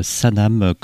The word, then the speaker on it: Sanam: À l'instant, dans l'asile le plus sûr, pour cette séquence introductive, on a démarré avec le second opus du combo libanais Sanam, comme ça se prononce, S-A-N-A-M,